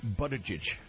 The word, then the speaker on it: Buttigieg: But, I say, how do you say it again? – Buttigieg. – Buttigieg. – Yeah. Okay. Or Buttigieg. Close enough.